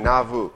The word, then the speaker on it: Nauvoo: Nauvoo, another point-making pronunciation, Nauvoo.